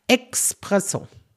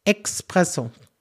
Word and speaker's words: Espresso: Da kriege ich auch immer schön äh, Espresso. Da ist kein X drin. Espresso.